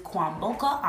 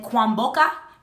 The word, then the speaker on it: Kwamboka: Okay, it's Judith, and then Kwamboka. K-W-A-M as in mom, B as in boy, O-K-A. Last name. – Don't try to pronounce it, though. – Last name, O-N-W-O-N-G-A.